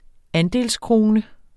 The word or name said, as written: andelskrone